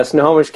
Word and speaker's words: Snohomish: Yeah, Snohomish County.